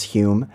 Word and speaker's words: Home: That's spelled like home, pronounced Hume.